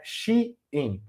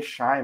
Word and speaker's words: Shein: O jeito certo de falar é Shein, porque é She do inglês e In de dentro também do inglês, né? Embora a, ela seja chinesa, aí tem gente que lê Shine, porque parece alemão, é uma confusão.